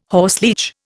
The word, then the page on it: horseleech: Indian